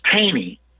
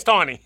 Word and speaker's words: Taney: It looks like Taney to me. I never understood that. It looks like Taney. I don't know why it's Taney. – Yeah. It's a Maryland thing. All right? He's from Maryland, Baltimore. Taney wrote that the Constitution was never intended for Black people because we were not humans.